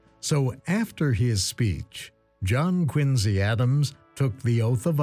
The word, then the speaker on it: Quincy: Historian Harlow Giles Unger wrote a biography of John Quincy Adams. Quincy, incidentally, is how the family pronounced it.